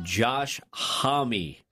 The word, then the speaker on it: Josh Homme: Somebody texted in, you pronounce his name Josh Homme.